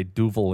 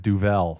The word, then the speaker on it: Duvel: I just had my last Duvel, and I keep pronouncing it incorrectly. Now I know to say Duvel instead of Duvel.